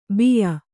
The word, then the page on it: ಬಿಯ್ಯ: biyya